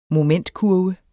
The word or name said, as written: momentkurve